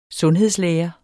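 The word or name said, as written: SUNDHEDSLÆRE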